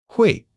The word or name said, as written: hui4